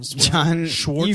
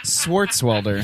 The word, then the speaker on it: Swartzwelder: John Schwartzwelder? – You fucking Nazi. John Swartzwelder.